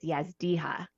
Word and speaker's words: Yazdiha: Did I say that right? – So close. It's Yazdiha.